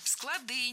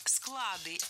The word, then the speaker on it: склады: Склады неверно. Нужно говорить склады, если речь идет о хранилище. В этом случае ударение остается на основе. Но если мы говорим, например, о чтении, то читаем по складам, ударяем окончание. Однако развозим товары по складам, ударяем корень. Ну а далее нам предстоит разобрать тройной омограф вместе с гостями маяка.